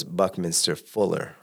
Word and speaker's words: Buckminster Fuller: Well, his mentor was Buckminster Fuller.